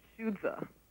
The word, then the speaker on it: Xuzi: And another that comes to mind from your book is something that we spell in our alphabet, X-U-Z-I. How do you pronounce that? – Shuzi.